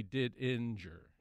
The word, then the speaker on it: Didinger: We always pronounce it Didinger, but I found out later that we've been mispronouncing it for generations. It should be We say Didinger, but we're actually pronouncing the G twice, yep. Which is probably incorrect. We should be Didinger.